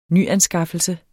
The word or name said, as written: nyanskaffelse